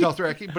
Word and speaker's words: Dothraki: I couldn't pronounce this Lothraki, but it says. – Dothraki? – Dothraki? – Dothraki, but it's Lothroig.